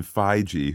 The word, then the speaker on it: Feige: And Pete, it's what Kevin Feige,